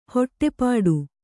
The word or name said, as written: ಹೊಟ್ಟೆಪಾಡು